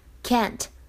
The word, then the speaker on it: can't: 整句话呢，在朗读过程当中，首先注意一下 can't。哎，这是美式的发音。如果英式的话呢，读的是 can't，can't，要注意一下英美的发音区别。